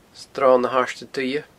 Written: sronnahairdeduibhe

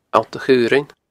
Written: Allt a Chaorainn

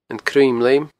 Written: Cruinn Leum

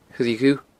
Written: A Choille Dhubh

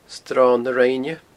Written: sronnarinne

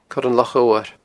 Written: coiranlochodhair

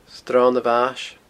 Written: sronamhais

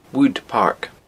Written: wood park

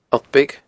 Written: Allt Beag

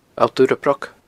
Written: Allt Urrabroc

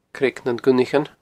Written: Creag nan Gunnaichean

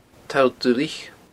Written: toll duilich

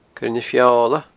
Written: coirenafeola

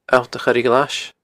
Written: alltachoireghlais

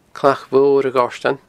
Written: Clach Mhor a Ghoirtein

